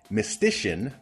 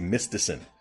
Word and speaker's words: Mistysyn: We start with uh, Pat Mastroianni and Stacie Mistysyn. That's right. She pronounces her name the way it's supposed to be pronounced. I always thought was Stacy Mystison.